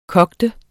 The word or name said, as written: koge